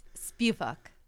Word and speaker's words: Spivak: It's Spivak.